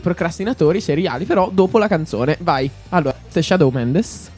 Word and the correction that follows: Shawn Mendes: Shawn Mendes, mamma mia la pronuncia.